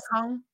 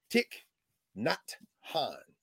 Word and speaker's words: Thich Nhat Hanh: Thich Nhat Hanh. – Yes. – Yeah. – Thich Nhat Hanh. Not like N-O-T. – Yes. – Hanh. And the tick is like a tick that burrows under your skin.